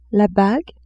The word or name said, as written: bague